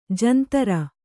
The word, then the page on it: ಜಂತರ: jantara